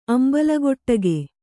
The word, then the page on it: ಅಂಬಲಗೊಟ್ಟಗೆ: Kannada